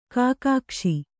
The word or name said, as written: ಕುಗ್ಗಿಸು